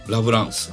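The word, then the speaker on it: 楽走術: かっこラブラン。 – ラブラン。楽装術どういう感じですかね。 – 楽して走る術、技と書いて楽装術。 – 楽して走る、はい、楽しく走るですね。 – はい、はい、術、うん、これラブランですね。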